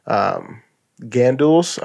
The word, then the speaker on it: gandules: Gandules? I don't know. No, that's how you say it. – Gandules? – Gandules.